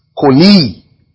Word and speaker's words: choli: Now, the word grief is an Hebrew word that is spelled choli, C-H-O-L-I, or K-H-O-L-E-E. The li is, you pull it, koli. The word sorrow, the word grief is koli. The word sorrow is makob, M-A-K-O-B, makob.